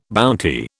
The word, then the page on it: bounty: British English